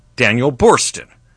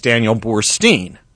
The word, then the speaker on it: Daniel Boorstin: And I thought it might seek favor with her because she had quoted him in class one day, although she mispronounced his name as Daniel Boorstein. No worries. There's a theory that maybe T-I-N could be pronounced teen.